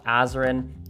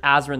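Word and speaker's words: Azren: It is at Polyglot Azrin. That is spelled P-O-L-Y-G-L-O-T, A-Z or Z, depending on your country, R-E-N. You can also simply search Azrin the Language Nerd on whatever your favorite social network is.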